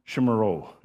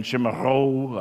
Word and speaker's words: chimarrão: but this idea of drinking chimarrão. And I'm not saying that right. I can't say it. It's supposed to be a kind of an H, a rolling H at the end, chimarrão, I don't know.